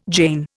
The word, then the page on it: jean: Indian